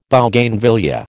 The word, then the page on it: Bougainvillaea: American English